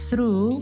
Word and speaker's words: through: Il TH in inglese può essere sordo o sonoro. E in particolare through ha un TH sordo, proprio perché noi dobbiamo mettere, per pronunciarlo bene, la punta della lingua tra i denti superiori. È un po' come Giovanotti quando cantava sono ragazzo fortunato perché mi hanno regalato un sogno.